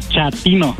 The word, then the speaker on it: chatino: Chapino. – ¿Chapino? – No, chatino. – Es como chato, ¿verdad? Chatino. – Sí, asunero, como chato, chatino.